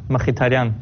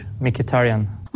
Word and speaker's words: Mkhitaryan: In Armenian you pronounce Mkhitaryan, in English Mkhitaryan.